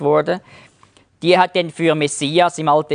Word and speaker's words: Messias: Es gibt übrigens keinen Sch-Laut im Griechischen. Darum hat das automatisch einen S-Laut gegeben. Also nicht Masch, schon Mess. Und sie haben dann nicht gesagt Messiach, sondern Messias. Das ist also die griechische Aussprache.